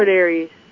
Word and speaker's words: Aquarius: I always say it wrong. Concarius? Did I say it wrong? – Aquarius.